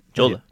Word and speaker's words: Jeolla: I don't know if I pronounced that right. – Yeah. Jeolla.